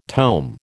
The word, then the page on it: tome: American English